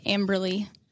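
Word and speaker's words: Amberlee: It's just my first name, Bryn, and then Amberly.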